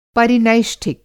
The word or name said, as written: परिनैष्ठिक